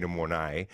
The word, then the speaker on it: mornay: I'm just also impressed by, given the way that you say it, it's not tuna mornay, it's tuna mornay. – Is it? – I don't know. You were saying tuna mornay with the emphasis on the second syllable.